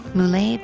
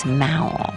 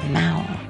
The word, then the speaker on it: Mowl: Moulet Paint and Glass. Actually, you know, I take that back. It's Mowl. It rhymes with owl. It's not Moulet. It's Mowl.